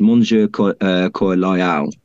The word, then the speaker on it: Monsieur Coeur Loyal: So the name's actually, the name Sir Loyalheart is, Henry would actually say it in French. I forgive my bad pronunciation, but it's Monsieur Coeur Loyal.